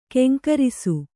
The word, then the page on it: ಘೀಂಕರಿಸು: ghīŋkarisu